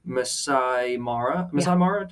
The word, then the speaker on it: Masai Mara: I'm going to have a link to the Masai Mara, did I pronounce it right? – Uh-huh. Okay. Yep.